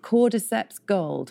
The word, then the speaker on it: Cordyceps: And then it's called, and I'm going to pronounce this wrong, Cordyceps Gold. – No, you pronounced it right.